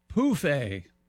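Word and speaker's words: pouffe: Anyway, Europeans of a certain age use the French word pronounced pouffet for an ottoman, phonetically spelled P-O-O-F hyphen A.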